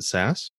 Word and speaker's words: Sass: I guess I should ask your name first, but is it Sass? – Yes.